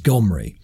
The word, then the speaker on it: Gomri: The name spelled, pronounced with that guttural G in Assyrian is Gomri.